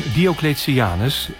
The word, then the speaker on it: Diocletianus: Nou, zal ik je even laten horen hoe het uitgesproken moet worden? – Ja. – Hier komt de Bob zelf. – Goed. De plek waar ik ben is... het paleis van Diocletianus in Split, Kroatië.